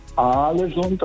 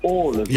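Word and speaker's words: Ålesund: Und zwei A's werden als O ausgesprochen und ein A mit einem Kringel oben drauf ist eigentlich auch eine Aussprache, ein O. Also das heißt nicht Aalesund, das heißt Aalesund.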